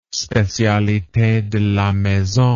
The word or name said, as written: specialite de la maison